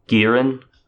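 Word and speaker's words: Girin (기린): So in Japanese, it's more like Kirin, with, like, it's spelled with a K. And in Korean, Girin.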